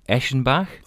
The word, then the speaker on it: Eschenbach: And I have to say, I'll try and get this right, because I bet for years I've been saying it wrongly. Eschenbach? – That's yep, good right.